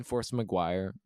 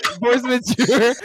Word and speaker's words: Force Majeure: I felt like just like because I haven't seen Force McGuire, however you say it. I know that's not how you pronounce it. Force Majeure. No, Force Majeure.